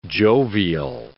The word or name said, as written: jovial